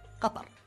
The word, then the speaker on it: Qatar: So just to recap, the official pronunciation of my country is Qatar. – Qatar. – So Qatar. Go ahead and close your throat a little bit. Make sure you say ta instead of ta, and you get Qatar.